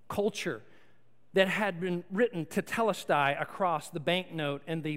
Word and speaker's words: tetelestai: Now, this little phrase, "it is finished," is actually a single Greek word that's pronounced tetelestai.